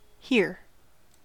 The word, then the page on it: here: American English